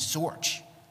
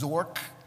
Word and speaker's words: Zorc: Okay, so got a Cleveland phone directory, called her up, and I told her, and remember what the pronunciation of her name was Zorch, okay, but it's spelled Z-O-R-C. So I called her up, I said, Mrs. Zork, because I didn't know any better.